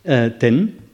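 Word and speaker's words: ten (天): Le mot « tien » chinois, par exemple, se prononce en sino-japonais « ten », alors qu'en pur japonais, c'est « ama », pour prendre un seul exemple.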